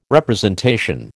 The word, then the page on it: representation: American English